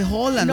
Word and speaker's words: Haaland: Pues porque se le dice Haaland. ¿Por qué tan cansón? Pues yo te estoy dando una información de que se dice Haaland.